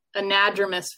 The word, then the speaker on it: anadromous: It was anadromous fish. I knew I pronounced it wrong.